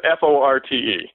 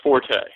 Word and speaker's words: forte: That the word uh, F O R T E is commonly pronounced forte. Most people say, you know, it's not my forte. – It's the correct fort. pronunciation of that word is actually fort.